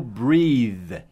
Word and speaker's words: breathe: Agora, respirar em inglês é o verbo breathe, breathe, B-R-E-A-T-H-E. B-R-E-A-T-H-E. Ele termina com E. E essa é a pronúncia. Breathe. O TH vai ter um som quase de V, só que com a língua no meio dos dentes. Z, z, z. Tá? Breathe. O E-A vai ter o som de I.